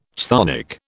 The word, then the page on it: chthonic: British English